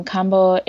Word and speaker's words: kambo: and I don't know what it is. So, will you share with us a little bit about it? – Yeah, absolutely. Um, so you did pronounce it correctly. Um, kambo